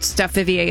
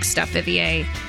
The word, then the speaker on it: Steph Vivier: Steph Vivier in the morning Instagram. You can also find us on Facebook, Steph Vivier in the morning.